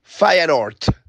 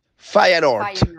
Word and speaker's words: Feyenoord: Aquí les va la verdad de cómo se pronuncia el equipo de Santiago Jiménez. Feyenoord. – Feyenoord. Fire Feyenoord. – Ah. Querido, muchas gracias. Clase corta y acelerada de pronunciación en el idioma en holandés.